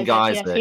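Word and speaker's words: Gajser: I don't want to misquote this or get it wrong, but I believe, I forget the pronunciation of his name, Geistje or whatever his name was, the Slovenian. – Tim Geiser. Yes, he won that MX.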